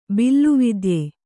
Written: ಬಿಲುವಿದ್ಯ

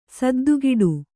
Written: ಸದ್ದುಗಿಡು

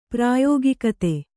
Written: ಪ್ರಾಯೋಗಿಕತೆ

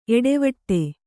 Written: ಏಟವೀಟ

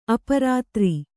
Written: ಅಪರಾತ್ರಿ

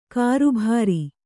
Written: ಕರ್ಬುರ

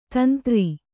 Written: ತಂದ್ರ

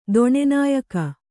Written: ದೊಣೆನಾಯಕ

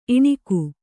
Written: ಇಣುಕು

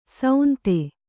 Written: ಸೌಂತೆ

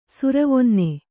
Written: ಸುರವೊನ್ನೆ

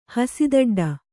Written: ಹಸಿದಡ್ಡ

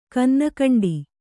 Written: ಕನ್ನಕಂಡಿ

